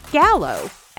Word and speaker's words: Gallo: Joe Gallo, actually. Gallo, spelled G-A-L-L-O, hilariously enough, is pronounced gallo in Spanish and means rooster.